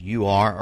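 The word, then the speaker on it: Er: That's is E-R, but it's pronounced U-R, Ur.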